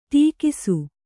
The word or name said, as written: ಟಕ್ಕಿಸು